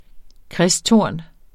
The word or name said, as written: kristtorn